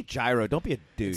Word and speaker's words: gyro: Yeah, it's spelled gyro, pronounced hero, but sometimes it's spelled hero in like the Midwest.